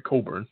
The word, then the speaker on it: Cockburn: The first thing on Google under Cockburn is, how is Cockburn pronounced? That's the first question. – It should be, because it's Cockburn. – Yes. The next question under that is, what does Cockburn mean? I'm sorry, Coburn.